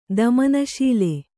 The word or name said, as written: ದಮನಶೀಲ